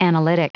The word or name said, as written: analytic